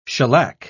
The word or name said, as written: shellac